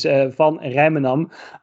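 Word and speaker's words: van Rijmenam: And the right way to pronounce my name is Van Rijsmenum, which is even in Dutch a difficult name, so you're excused.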